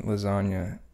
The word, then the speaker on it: lasagna: So the G in lasagna, to spell lasagna, you know, if you just started speaking English to like the other day, you'd think it's spelled L-A-S-A-N-Y-A, you know, if you were...